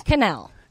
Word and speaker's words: Quesnelle: Quesnelle. – Canel would have never gotten that out of there. – No, nobody ever does, unless you're a French teacher.